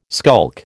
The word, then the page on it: skulk: American English